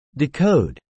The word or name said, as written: decode